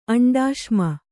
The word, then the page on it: ಅಂಡಾಶ್ಮ: kannada